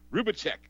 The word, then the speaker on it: Rubacek: Rubacek.